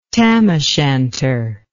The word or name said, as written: tam o' shanter